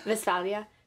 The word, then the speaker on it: Westphalia: It's so, really like Westphalia, but that's okay. Wait, say it again. Westphalia. So all W's are V's in German.